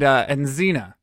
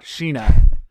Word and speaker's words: Xena: And Xena, no matter what Sawyer says, it's not Sheena.